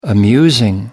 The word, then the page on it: amusing: British English